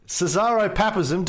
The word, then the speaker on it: Caesaropapism: Cesaropapism.